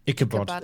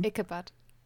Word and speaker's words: Ichabod: Itchebode? – Ichabod. Ichabod. – Ich was? No? Hm? Ichabod. Ichabod, ja, das klingt professionell. Ichabod.